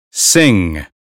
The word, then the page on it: sing: American English